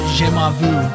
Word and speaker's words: jamais vu: I was spending too much time trying to like brain processing how to pronounce jamais vu.